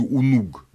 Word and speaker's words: Унуг: Урук по-шумерски произносился Унуг, но это не так нам интересно.